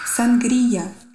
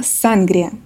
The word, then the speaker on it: сангрия: Сангрия. Я, кстати, когда разговаривала с каждым из гостей, я оба раза сказала «сангрия», и они меня оба поправили. Так что запомните, правильно говорить «сангрия».